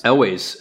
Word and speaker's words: Elwes: Elwes. – Carrie Elwes.